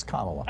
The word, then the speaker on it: Kamala: Fox News, and Trump mispronounces it as a way of slurring her. It's Kamala.